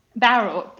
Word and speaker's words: Bowral: Now, I think I do I pronounce it Bowral? – Bowral, Bowral, Bar- yes, Bowral. Bowral. Okay, yes.